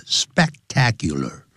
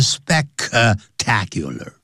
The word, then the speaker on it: spectacular: spectacular 因为有一个 S-P-E-C 所以念的时候要停顿一下 spectacular 不要念成 spectacular、啊、 spectacular 很